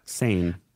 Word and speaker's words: Seine: The big show with the opening ceremony on the River Seine did I say it right? River Sen? I think so. – Sen. Uh, July 26 marks the first time an opening ceremony will be held outside of a usual stadium setting. So that's kind of a big deal that they're doing that. Sane. Sane. Sane. Sane. River Sane. That's the American um, pronunciation.